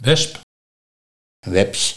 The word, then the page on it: Weps: Nieuw lekkerlands; dialect